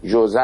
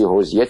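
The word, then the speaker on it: José: جمله جالبی خود اون راوی داره، میگه نام من جوزه است، اینطور تلفظ میشود در فیلیپین در زبان انگلیسی حوزیه تلفظ میشه، در عربی و اسپانیایی خوزه تلفظ میشه، در پرتغالی جوزه تلفظ میشه و اینجا کویت.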